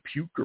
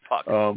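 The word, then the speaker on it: Puk: Is it Puke or Puck? I never figure out how to pronounce him. – It's Puck.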